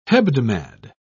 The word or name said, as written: hebdomad